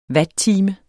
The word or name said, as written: watttime